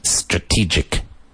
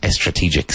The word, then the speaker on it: strategic: S followed by consonant is not preceded with an E sound. Strategic. Not estrategic. Strategic.